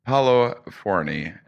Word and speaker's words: Paolo Forni: Today, I'm at the University of Albany talking to assistant professor in the Department of Biology, Paolo Forni, is joining me, everybody.